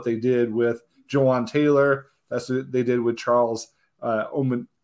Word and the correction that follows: Omenihu: All right, give me a pronunciation. – Omenihu. – I'm not used to it yet. Charles Omenihu.